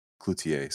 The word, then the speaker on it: Cloutier: Cloutier, sorry.